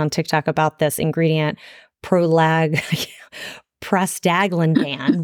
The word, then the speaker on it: prostaglandin: The reason why I like this is because it doesn't contain an ingredient called prostaglandin. I don't know if I'm even saying it right. P-R-O-S-T-A-G-L-A-N-D-I-N.